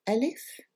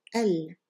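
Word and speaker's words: el (ال): Let's draw the line right here and uh, it's made up of an alif and a lam, and it's pronounced el. And you're probably wondering why it's not pronounced al, because this is a long a, right? Well, let's see. The reason is that that long a that you see in the definite article is in fact an alif with a hamza and a fatha on top. So it's basically a hamza followed by a vowel a, a, short a, and then the letter lam, l.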